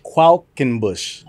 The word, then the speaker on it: Qualkenbush: So it's Qualkenbush.